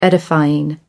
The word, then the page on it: edifying: American English